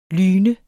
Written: lyn

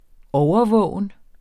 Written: overvågen

